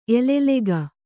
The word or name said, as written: ಎಲೆಲೆಗ